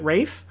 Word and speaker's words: Ralph: just because his name is Ralph and he pronounces it Rafe.